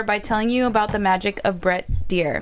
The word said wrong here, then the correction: Dreyer: Did I pronounce his name right? – Dreyer.